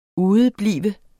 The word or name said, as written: udeblive